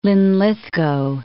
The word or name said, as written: Linlithgow